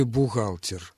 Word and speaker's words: бухгалтер: В слове «бухгалтер» не читается сочетание «ХГ». Не читается. На месте этого сочетания читается «Г» – «бухгалтер».